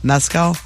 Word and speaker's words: Mescal: And Mescal, Paul, how do you say his name? – Mescal? – Mescal.